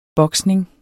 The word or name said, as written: boksning